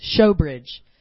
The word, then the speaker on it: Shewbridge: He would say, Miss Shewbridge, because it's like the showbread in the Old Testament.